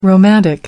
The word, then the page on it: romantic: American English